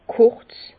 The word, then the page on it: kurz: Bas-Rhin; Herrlisheim